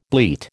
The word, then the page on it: bleat: American English